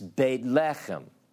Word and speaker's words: Beit Lechem: If I say to you Bethlehem, or properly pronounced in Hebrew, it's Beit Lechem.